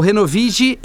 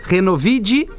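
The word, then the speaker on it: Renovig: O Renovid é com G, tá? Renovid é com D a pronúncia, mas a escrita é com G.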